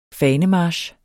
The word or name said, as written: fanemarch